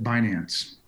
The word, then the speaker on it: Binance: Is it Binance or Binance? How do you pronounce it? Do you know? – It ain't Binance. It ain't bananas. Most of us call it Binance.